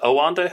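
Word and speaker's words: Oanda: Is it Owanda how it's pronounced? – Yeah.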